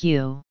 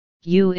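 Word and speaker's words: you (鱼): No matter what form it is written in, the word for fish in Chinese is pronounced like you. Cultural importance of fish in China. The pronunciation of fish in Chinese, yu, is a homophone, for abundance.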